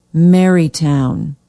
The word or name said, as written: marytown